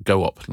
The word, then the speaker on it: Goop: I pronounce it go up, like co-op.